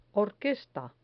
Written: orquesta

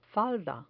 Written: falda

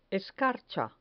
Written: escarcha

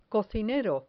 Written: cocinero

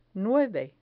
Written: nueve

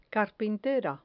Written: carpintera

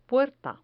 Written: puerta

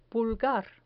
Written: pulgar

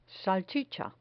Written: salchicha

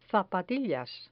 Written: zapatillas

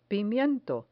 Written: pimiento

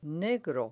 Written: negro

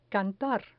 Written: cantar

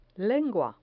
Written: lengua

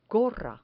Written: gorra

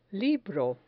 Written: libro

